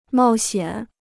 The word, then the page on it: 冒险: mào xiǎn